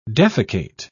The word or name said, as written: defecate